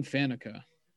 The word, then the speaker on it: Faneca: So let's start with Alan Faneca, or however it is you pronounce that, because Faneca is correct.